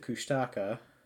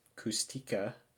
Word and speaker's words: Kushtaka: My sister Megan recently told me that for a long time she believed the Kushtaka, often pronounced Kustika were frighteningly real,